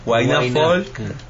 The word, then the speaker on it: Huayna Folk: Huayna Folk, WAYNA, acento en la A, y Folk como se escribe, FOLK. Así que para los que quieran seguirnos, abierto nuestras redes para toda la audiencia.